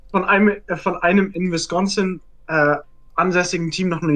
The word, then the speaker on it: Wisconsin: Er ist von den Green Bay Packers, also einer, einem in Wisconsin, sorry, Wisconsin, das ist immer noch falsch gesagt. Ihr wisst, was ich meine. – Wisconsin.